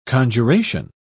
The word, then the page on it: conjuration: British English